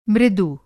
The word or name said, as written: मृदु